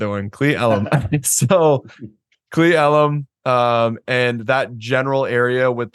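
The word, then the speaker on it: Cle Elum: You have your, I'm going to say this wrong, Seal Illum. – You did say it wrong. – Did I get it right? – No. – Okay, I didn't think. How do you say that? – Klee Elum.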